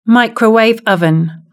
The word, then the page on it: microwave oven: British English